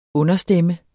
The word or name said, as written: understemme